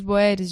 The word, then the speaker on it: boer: Os boeres de boer, que em é holandês significa camponês e se pronuncia boer, também denominados africaners, são os descendentes dos holandeses e franceses instalados no século XVII na região do Cabo de Boa Esperança.